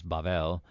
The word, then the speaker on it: Babel: of Babel is actually the Hebrew word is Babel, which we pronounce that V as a B.